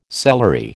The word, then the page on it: celery: American English